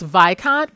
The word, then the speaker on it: Viscount: And so, okay, so it is pronounced Viscount, but it's spelled Viscount.